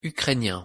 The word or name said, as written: ukrainien